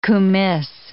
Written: koumiss

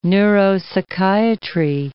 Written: neuropsychiatry

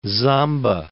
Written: Zomba

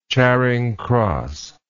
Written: Charing Cross

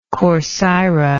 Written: corcyraean

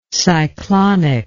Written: cyclonically

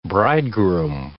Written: bridegroom

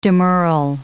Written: demurral

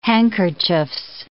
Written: handkerchieves

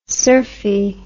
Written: surfie

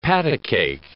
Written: pat-a-cake

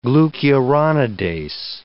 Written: glucuronidase

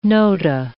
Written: nota

